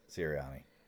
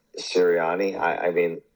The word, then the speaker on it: Sirianni: Sirianni. – Sirianni.